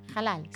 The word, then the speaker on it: halal: Lo digo, lo pronunciamos bien nosotros. Halal, carne, sí, halal.